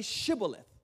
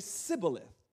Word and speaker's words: Shibboleth: If the man said, no, I'm not, they would tell him to say Shibboleth. If he was Ephraim, he would say Sibboleth, because the people of Ephraim cannot pronounce the word correctly.